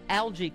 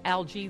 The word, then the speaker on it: algae: The word algae can be pronounced two ways. Algae, the British English. Algae.